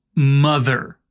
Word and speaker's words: mother: Percebeu que quando você chegou no TH da palavra mother, a sua garganta vibrou? Bem, se ela não vibrou, provavelmente você está pronunciando um pouco erradinho essa palavra.